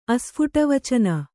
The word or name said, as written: ಅಸ್ಫುಟವಚನ